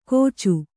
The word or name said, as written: ಕೋಚು